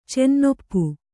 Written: ಚೆನ್ನೊಪ್ಪು

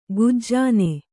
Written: ಗಜನಿ

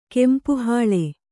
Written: ಕೆಂಪುಹೂಲಿ

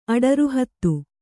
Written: ಅಡರುಹತ್ತು

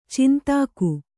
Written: ಛಂದಕ

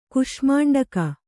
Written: ಕುಷ್ಮಾಂಡಕ

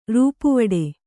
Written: ರೂಪುವಡೆ

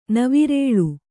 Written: ನವಿರೆಳೆ

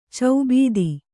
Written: ಚೌಬೀದಿ

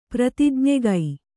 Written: ಪ್ರತಿಜ್ಞೆಗೈ

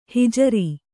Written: ಹಿಜರಿ

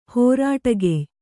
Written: ಹೋರಾಟಗೆಯ್

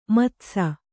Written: ಮದಿಸು

